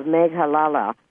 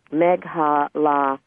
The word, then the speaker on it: Meghalaya: You have information here about the Indian state of Meghalaya. I didn't say that right. Meghalaya, that's what it is.